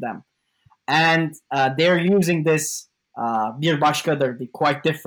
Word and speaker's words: Bir Başkadır: No, I'm not going to pronounce it. Please pronounce it. – It's Bir Başkadır.